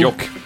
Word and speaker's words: Jokke: J-O-K-K-E. – From yep. Finland. – Yoke.